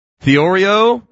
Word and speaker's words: theoreo: It's spelled like the Oreo. Literally, like the Oreo cookie. T-H-E-O-R-E-O. Theorio means to see or look at. It means almost exactly what S-E-E means in the English, right?